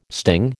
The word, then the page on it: sting: American English